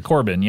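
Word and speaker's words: Corbijn: And they always worked with old Sourpuss, Brian Eno, and they always worked with Anton, how do you say his last name? – Corbin, yeah.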